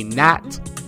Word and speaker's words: gnat: The small two-winged fly that resembles a mosquito is a gnat, the G being silent,